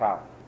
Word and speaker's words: kachal: What is it pronounced the word? That's the yeshiva to pronounce it kachal.